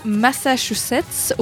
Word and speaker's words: Massachusetts: L'entreprise a été créée en 1969 au Massachusetts, aux États-Unis. J'ai réussi à le dire juste.